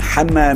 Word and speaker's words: حنا: حنا نايل والحقيقه انا غلطت الحلقه اللي فاتت ونطقت اسم حنا على انه هنا